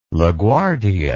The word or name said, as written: LaGuardia